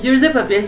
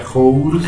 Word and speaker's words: Jours de Papier: Y Jules de Papier se escribe J-O-U-R-S. – O sea, Jules. – Ajá, Jules, no Jules, como J-U.